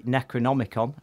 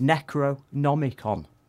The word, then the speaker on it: Necronomicon: And the Necronomicon, and I said it properly. A lot of people don't, including me, I forget sometimes. It's not an easy word, is it? – The Necronomicon. – Necronomicon.